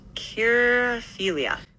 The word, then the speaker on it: quirofilia: Q-U-I-R-O-F-I-L-I-A. – Okay, that would be pronounced kirophilia.